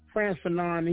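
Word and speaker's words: Frantz Fanon: So, tell us about your experience in learning about France Fanon, if I've said his name correctly. – Yes, yeah. France Fanon, he was part of the revolutionary mind change when it came to the liberation.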